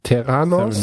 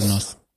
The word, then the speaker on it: Theranos: Das Ganze ist, äh, oder Elizabeth ist die Gründerin von, wie spricht man es richtig aus, Terranos. Theranos.